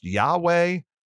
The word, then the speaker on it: Yahweh: We have to put in the A and the E vowels to be able to pronounce it. But others have put in an E and an O and an A in the word, leading it to be pronounced Yehovah, or finally, Jehovah. So this important name of God, which means I am, can be pronounced Yahweh or Jehovah.